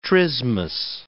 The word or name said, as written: trismus